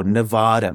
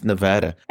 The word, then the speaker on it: Nevada: Apparently, her character was born in a place called Boulder City, Nevada. I loved how she actually said Nevada correctly. Not like I hear a lot of people say, oh, Nevada. No. It's Nevada.